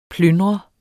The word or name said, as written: plyndrer